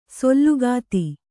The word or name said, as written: ಸೂಲಗಿತಿ